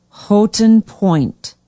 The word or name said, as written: houghton point